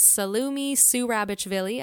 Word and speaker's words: Salome Zourabichvili: So the next woman on my list is Salome Zourabichvili. I hope I'm saying that right. – You did.